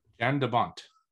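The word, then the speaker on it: Jan de Bont: Jan DeBont.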